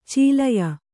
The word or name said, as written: ಚೀಲಯ